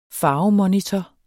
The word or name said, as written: farvemonitor